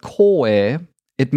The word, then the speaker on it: Koei: I mean, you might have heard of Koei Tecmo. And the proper pronunciation, though, because it's a Japanese term, would be Koei, which is usually spelled in katakana. So it's just like Koei.